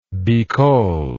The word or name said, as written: bikol